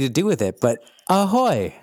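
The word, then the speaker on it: Ahoj: Ahoy. I'm doing well. Ahoy is how you say hello in Czech. – Yeah, ahoy.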